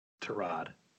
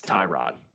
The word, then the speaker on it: Tyrod: How do you pronounce the former Buffalo Bills quarterback, Taylor, his first name. – Tyrod. – It's Tyrod. Tyrod.